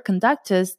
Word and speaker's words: conductus: And the word conductus does sound like conductor a little bit. It's spelled very similar, C-O-N-D-U-C-T-U-S.